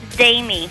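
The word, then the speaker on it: Dami: Did you say Jamie? – Damie.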